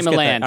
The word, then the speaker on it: Milland: Is it Millard? – Milland. – Ray Milland. I always get that mixed up.